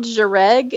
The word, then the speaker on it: Jhereg: The first one is called Jereg. It's spelled Jhereg, and that's the one I finished.